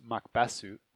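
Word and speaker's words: Makbasu (マクベス): Oh, but then the other funny part was in Japanese, Macbeth would be pronounced Makbasu. And they changed it to Macross, which is pronounced very similarly.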